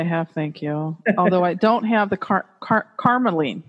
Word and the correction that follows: carnelian: Wait, how do you say carnelian? – Carnelian. Excuse me.